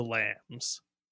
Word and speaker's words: LAMS: Oh, this is Australia, so it's most definitely pronounced the lambs.